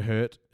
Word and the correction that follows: Hurts: is hurt. Sorry, I said that wrong. Jalen Hurts is hurt.